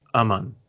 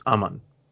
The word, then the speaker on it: Aman: So my name is actually pronounced Aman. So the best way that I can describe it is as in like and mun as in money. So if you can combine that very quickly, it's Aman.